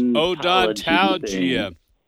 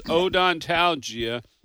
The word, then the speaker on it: odontalgia: Yes, Odontalgia. Hey, Philip, it's Odontalgia.